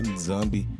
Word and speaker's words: Nizambi: Em Zambi, a, a, pronúncia correta, né? Nizambi.